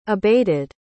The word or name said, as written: abated